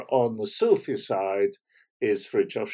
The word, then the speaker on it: Fritjof: What about the first name, though? – Ah, Fritjof. So Fritjof Sean.